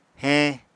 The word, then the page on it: heñ: apatani